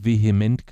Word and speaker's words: VHEMT: bin ich auf die Bewegung Vehement gestoßen. Kennst du die? – Nee. – Das ist die Voluntary Human Extinction Movement. Das heißt, ausgesprochen, also abgekürzt VHEMT, aber ausgesprochen wird es vehement, ist eine Bewegung, und zwar eine Bewegung von Menschen, die sich um das Leben auf dem Planeten Erde sorgt.